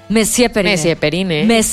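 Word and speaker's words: Monsieur Periné: Messier Periné.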